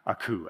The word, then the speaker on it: Aku: Aku, actually, is how it's spelled, or it's pronounced.